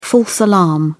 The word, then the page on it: false alarm: British English